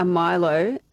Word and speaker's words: Milo: She's asking for a Milo and it was on the menu. And then they said, Milo. So that's how they say it over there.